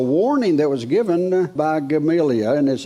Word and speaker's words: Gamaliel: I looked that up on how to pronounce it on the internet, and I heard two or three different types, so I thought I'm just going to redneck it. He's Gamaliel. That's just the way it comes out of my mouth.